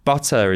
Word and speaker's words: butter: Now, butter is pronounced with a D here, and it was the T that was throwing people off.